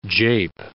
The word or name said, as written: jape